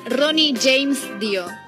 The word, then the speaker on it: Ronnie James Dio: que sería Ronnie James Dio.